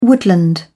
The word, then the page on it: woodland: British English